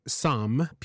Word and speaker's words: psalm: The letter P is often silent in words that pair it with the letter S, as in psalm, P-S-A-L-M, as in the book of the Bible, psyche, psychology, pseudoscience, pseudonym, and core. It's funny because the last one is C-O-R-P-S, and we don't pronounce the P or the S.